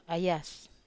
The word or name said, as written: ayas